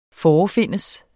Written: forefindes

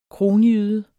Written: kronjyde

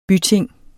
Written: byting

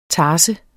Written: tars

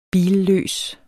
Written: billøs

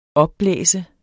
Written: opblæst

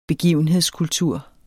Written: begivenhedskultur

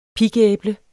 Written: pigæble

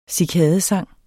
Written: cikadesang

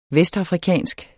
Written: VESTAFRIKANSK

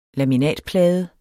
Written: laminatplade

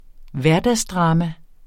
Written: hverdagsdrama

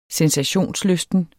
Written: sensationslysten

